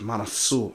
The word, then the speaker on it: Mansoor: That's actually how you pronounce it, Mansoor.